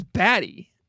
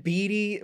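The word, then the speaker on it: Bede: Their name is Batty. – It's B. Isn't it Beedy? Why? It's B-E-D-E. You think that's Batty? How is that Batty? – Betty.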